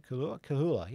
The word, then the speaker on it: Kahula: The 7th was the 2-year-old Fillies Final, taken by Kahula. I think that's how you say it. Kahula, yeah. Emma Stewart and Mark Pitt there.